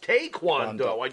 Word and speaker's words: Taekwondo: It's not Taekwondo. Taekwondo. It's Taekwondo. properly pronounced Taekwondo.